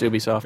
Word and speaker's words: Ubisoft: No, you're right. It's Ubisoft.